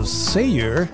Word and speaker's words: Cejer: Vediamo un po' come si pronuncia, è scritto C-E-J-E-R, quindi Richard Sayer.